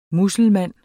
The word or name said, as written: muselmand